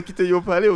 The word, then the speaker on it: Kiteyo Palais: Kiteyo Palais, est-ce que je prononce bien là Ouais, c'est bien ça.